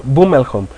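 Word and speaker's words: Bruttenholm: Alguien que se me está pasando y que es muy importante mencionar es Trevor Boomelholm, así se pronuncia, es Trevor Boomelholm.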